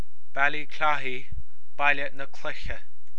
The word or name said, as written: Baile na Cloiche